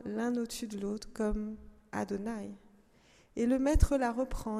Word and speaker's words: Adonai: il ne faut pas prononcer ces lettres-là. Il faut prononcer Adonai, c'est-à-dire mon Seigneur.